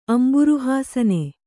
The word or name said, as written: ಅಂಬುರುಹಾಸನ